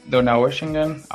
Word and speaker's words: Donaueschingen: Nada, la ciudad se pronuncia Donaueschingen, aunque todo el mundo le llama Donau.